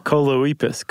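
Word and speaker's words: Choloepus: There are the two-toed Colepus. – I was going Colepus? With Coleoepus. – Oh. – Coleoepus. Coleoepus. – Coleoepus? – It rhymes with Bocephus.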